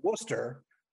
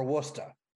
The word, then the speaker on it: Worcester: So it's either Worcester or Worcester.